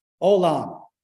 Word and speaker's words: Olam: The word in Hebrew for everlasting in Jeremiah chapter 31, verse 3, that word for everlasting is spelled in English, Olam. O-L-A-M, Olam.